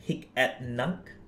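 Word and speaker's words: Hic et Nunc: So there's one which is under the Tezos blockchain, which is H-E-N, Hik at Nunk. – Yes. Oh, you pronounce it well. Yes, perfect.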